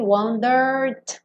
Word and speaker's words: wandered: Também não vai pronunciar o último E. Então, do R, wandered. Já vai pro D.